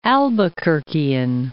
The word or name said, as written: Albuquerquean